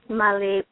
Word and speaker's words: Mali: It's Molly Phonpadith.